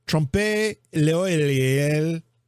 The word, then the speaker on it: trompe l'oeil: It's T-R-O-M-P-E, trompe. And it's l'oeil, which is L apostrophe O-E-I-L. L apostrophe O-E-I-L. Trompe l'oeil.